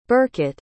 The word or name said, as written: birkett